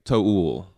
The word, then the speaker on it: To Øl: We talked about this before, but a lot of people say Tool because it's yeah. just T O O L, but the second O you know, it's got the line through it. And so, I, I, yeah, after doing a little bit of research, it seems like the proper way to say it is like Toul.